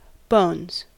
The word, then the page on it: bones: American English